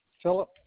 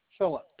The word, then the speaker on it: Philip: Philip Cheatwood. And I initially purchased something for $5.95. – Can you spell that first name for me? – Philip. P-H-I-L-I-P. Philip. – And that last name? – Cheatwood. C-H-E-A-T-W-O-O-D.